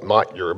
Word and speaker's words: Motyer: I like this quote from the Old Testament scholar and sometime pastor, Alec Motyer, spelled M-O-T-Y-E-R. It looks like Motyer, but it's pronounced Motyer.